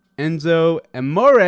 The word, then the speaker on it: Enzo Amore: Enzo Amore.